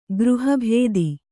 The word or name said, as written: ಗೃಹಭೇದಿ